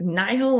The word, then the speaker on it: Nihil: Is it nihil, like nihilism? Or is it like I took Latin for like 5 years and it would be if it's just a word pronounced nihil, but we're not speaking Latin. So you're probably right. – Yeah, from nihilism.